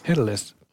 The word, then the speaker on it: Helles: Hur uttalas man Helles på det finska? Helles. – Helles.